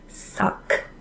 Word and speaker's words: Sakh: So Siri tells Georgie that Bechdel is called a modified suck. – Suck. – Suck. So it's spelled like S-A-K-H.